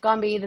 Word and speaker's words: Gombe: Gombe. Am I pronouncing that right? – Yeah, I think you Oh. – are. Yeah, Gombe.